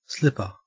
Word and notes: slipper: Australian English

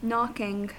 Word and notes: knocking: American English

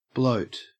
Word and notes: bloat: Australian English

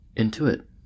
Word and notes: intuit: American English

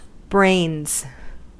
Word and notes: brains: American English

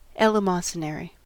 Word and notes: eleemosynary: American English

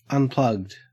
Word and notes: unplugged: Australian English

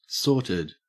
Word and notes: sorted: Australian English

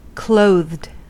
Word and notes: clothed: American English

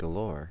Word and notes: galore: American English

